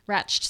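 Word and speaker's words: Radch: Signed first edition of Anne Leckie's Imperial Radic. Is that how you say it? – Ratched series with themed tea.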